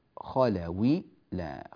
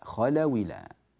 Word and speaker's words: خلوا إلى: سوف نأخذ هذه الكسرة وننقلها إلى الواو فتصير خلاوي، ونحذف هذا السكون ونسقط الهمزة كذلك، فيصير النطق النهائي هكذا: وإذا خلاوي لا خلاوي لا، هكذا لاحظوا، وإذا خلاوي لا خلاوي لا، لا يوجد مانع للساكن الصحيح قبل المنفصل.